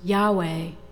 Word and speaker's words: Yahweh: The Hebrew pronunciation of those letters, Yahweh, is an attempt to imitate the sound of inhalation and exhalation. You breathe in Yah and breathe out Veh, but you don't make any sound other than the breath.